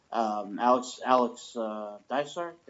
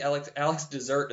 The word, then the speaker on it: Dysart: I want to say Alex Dessert is what I said for the longest time, but I'm pretty sure that's wrong. – Reads, like Alex Dessert.